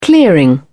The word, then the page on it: clearing: British English